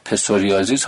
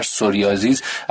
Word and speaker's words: پسوریازیس: بیماری پسوریازیس، حالا پی اولش گاهن خونده نمیشه برای تلفظ راحت تر، سوریازیس ازش نام برده میشه.